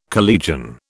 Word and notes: collegian: British English

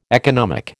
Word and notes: economic: American English

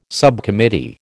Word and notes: subcommittee: American English

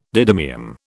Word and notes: didymium: British English

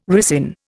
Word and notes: ricin: Indian

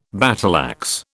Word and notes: battleaxe: British English

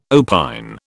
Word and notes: opine: British English

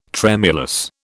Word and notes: tremulous: British English

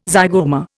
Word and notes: zygoma: Indian

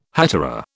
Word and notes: hetaera: British English

